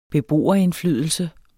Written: beboerindflydelse

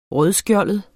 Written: rødskjoldet

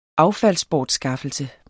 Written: affaldsbortskaffelse

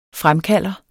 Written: fremkalder

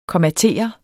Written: kommatere

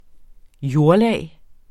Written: jordlag

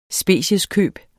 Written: specieskøb

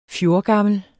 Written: fjorgammel

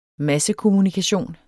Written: massekommunikation

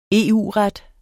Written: EU-ret

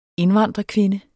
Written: indvandrerkvinde

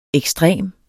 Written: ekstrem

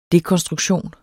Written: dekonstruktion

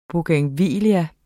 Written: bougainvillea

